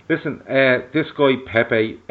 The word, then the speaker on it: Pepe: Now, the one we're going to touch on, because it's been the strongest link all week, is Nicolas Pepe.